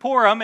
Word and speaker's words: Purim: Mordecai inaugurates this holiday to celebrate their deliverance from Haman, and they call it the Feast of Purim, and I had to train my brain to say that correctly because I read those letters and I say, that is not how I should say that, but apparently it is.